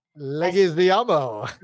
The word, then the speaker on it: Leguizamo: Leguizamo, is that how you say it? – Leguizamo.